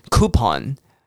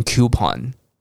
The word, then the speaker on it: coupon: 像有一个子音叫 u，很常被念成母音的 u，比方说像 coupon。有些人会念成 coupon，对不对？那都可以啊。